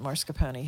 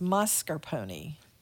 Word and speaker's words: mascarpone: I've been pronouncing it mascarpone, haven't I? It's mascarpone. Okay.